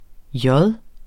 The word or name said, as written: J